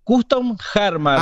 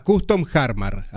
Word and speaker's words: Custom Jarmar: ¿Lo pronuncié bien? ¿Así es? ¿Custom Jarmar o Jarmar? ¿Cómo es? – Custom Jarmar. – Ah, Custom Jarmar. Así, como suena.